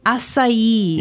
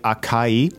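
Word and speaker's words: açaí: Acai. – Yeah. – That's a very complicated pronunciation for a very short word. – Yeah, it's A-C-A-I, so it looks like acai. – Yes. – But it's acai.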